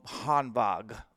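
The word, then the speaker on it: Hanwag: You still know Hanvag. I think I'm saying that right. – Yep, you are.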